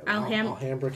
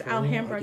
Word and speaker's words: Alhambra: It's A-L, ham, and bra. Alhambra? – Or is it Alabama and it just got spelled wrong? – No, because it's California. – Oh, okay. – Alhambra, California. – It's Alhambra, California.